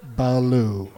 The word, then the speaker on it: Ballou: I'm not just adding a syllable to the word blue. It's Ballou.